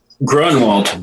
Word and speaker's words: Grunewald: How do you say your last name, Peter? – Grunewald. – Grunewald. Okay, good. Now I'll know how to say it. Is that German or something? – And it's very German, yes. Yeah.